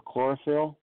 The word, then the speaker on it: chlorophyll: Apparently plants in its family either produce no chloroform or don't produce enough chloroform or chlorophyll, the green stuff in your leaves that help them convert sunlight into energy.